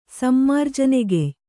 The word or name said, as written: ಸಮ್ಮಾರ್ಜನೆಗೆಯ್